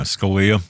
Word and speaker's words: Scalia: Yeah, Scalia.